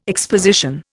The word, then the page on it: exposition: Indian